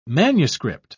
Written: manuscript

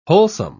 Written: wholesome

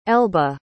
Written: elba

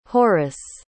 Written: horace

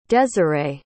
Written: deserae

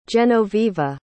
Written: genoveva

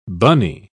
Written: bunny

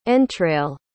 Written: entrail

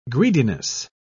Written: greediness

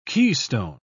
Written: keystone

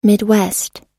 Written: midwest

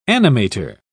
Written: animator

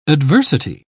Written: adversity

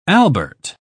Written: albert

